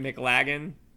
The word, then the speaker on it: McLaggen: Is that how you say it? McLaggen? – Yes.